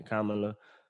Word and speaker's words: Kamala: I finally learned how to say her name. Kamala. Yeah, and a host of others.